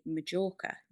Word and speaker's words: Mallorca: Some people in the UK say Majorca, depends on how it's written, because you can write it with a J or a double L.